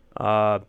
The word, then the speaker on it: Barth: the TH is not pronounced as we would, and it's so easy to try and swap it out for an F because it just rolls right off the tongue, but it's pronounced Bart, as in like Bart Simpson.